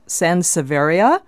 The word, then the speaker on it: Sansevieria: She said she recently bought a nice-sized Sansevieria. – That's the one. – That's the one? Did I say it right? – That's right.